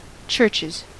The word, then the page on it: churches: American English